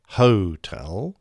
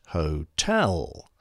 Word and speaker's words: hotel: For example, hotel isn't hotel, it's hotel. Generally speaking, or perhaps more correctly, phonetically speaking, the emphasis is on the first syllable, except for November and Sierra, the middle, Papa, and Quebec at the end.